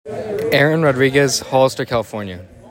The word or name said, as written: Aaron Rodriguez II